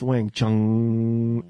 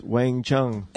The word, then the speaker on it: Wang Chung: It's not pronounced Wang Chung. It's Wang Chung.